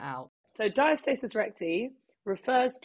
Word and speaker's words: diastasis: Um, you've talked to a fair bit about, and I'm probably not going to say this right, Diatesis recti. Is that yes? Have yeah, I said diastasis. that right? Oh, okay. Yeah, diastasis.